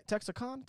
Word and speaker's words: Texarkana: Texarkana.